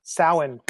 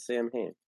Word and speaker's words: Samhain: And the whole beginning of that book starts with the Samhain, and I'm saying it wrong. Say it again. – Samhain. – Samhain. Oh, really? I always said Samhain.